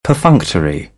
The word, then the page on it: perfunctory: British English; ə